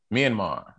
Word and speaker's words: Myanmar: What is it, Myanmar? Is that correct? – Myanmar.